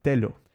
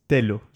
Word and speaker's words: telo: So today we have the word telo. It's telo. So that is spelled in Tokipona T-E-L-O, right?